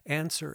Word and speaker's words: Anser: Answer is spelled A-N-S-E-R.